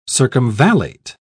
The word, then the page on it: circumvallate: British English